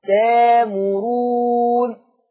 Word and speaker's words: تأمرون: تأمرون همزة ساكنة هتبدل بألف مادية فتقرأ تامرون. تامرون.